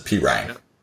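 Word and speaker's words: Perine: And it's going to be because of Pirine here, Samaje Pirine. – Samaje. So I have his name spelled P-E-E-R-Y-N-E now. So I will remember how to pronounce it. Pirine.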